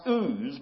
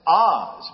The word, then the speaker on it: Oz: And it's not pronounced ooz, but it's pronounced in the Hebrew just like it says, Oz.